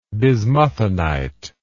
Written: bismuthinite

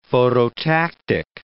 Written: phototactic

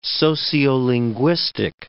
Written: sociolinguistic

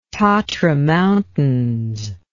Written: tatra mountains

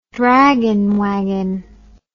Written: draggin wagon

